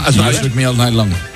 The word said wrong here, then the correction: Shook Me All Night Long: Qué me cuesta el Shook Me All Night Long.